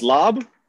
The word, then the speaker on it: larb: Is it pronounced lob? – That's the way it sounded when I was in Laos.